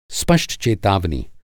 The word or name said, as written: स्पष्ट